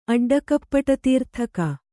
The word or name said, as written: ಅಡ್ಡಕಪ್ಪಟತೀರ್ಥಕ